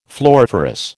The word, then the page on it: floriferous: American English